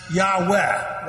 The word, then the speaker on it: Yahweh: Whenever you see that, when you're reading the Old Testament, that's the word for, well, the old traditional pronunciation was Jehovah. Typically today you'll hear it pronounced Yahweh, Y-A-H-W-E-H. It's just simply two ways of pronouncing.